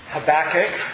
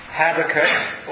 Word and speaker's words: Habakkuk: We turn to Habakkuk, or Habakkuk, or Habakkuk. It's pronounced by different people different ways.